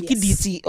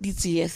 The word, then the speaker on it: Ditsie: And so now we've got the right pronunciation. Ditsie. Okay, got it. Not Ditsie. Thank you.